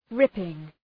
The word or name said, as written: ripping